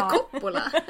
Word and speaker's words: Coppola: Och sms från Soppola. Soppola. – Alltså Coppola.